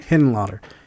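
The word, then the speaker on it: Henenlotter: Hennenlotter. – Frank Hennenlotter.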